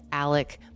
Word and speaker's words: Alec: And the father in this family is named Alec Murdaugh, even though the spelling looks like Alex Murdaugh.